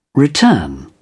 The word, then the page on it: return: British English